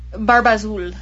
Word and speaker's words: Barbazul: In 2012, it's Babarzu. Is that how it's pronounced? – Barbazul. – Barbazul, okay.